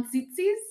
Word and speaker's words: Tzetzes: So John Tzitzis? – Yeah, it's spelled T-Z-E-T-Z-E-S.